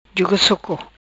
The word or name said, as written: Juga soco